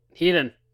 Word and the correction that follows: Hiei: Hiei won, yes. You can't say his name properly, but Hiei did win.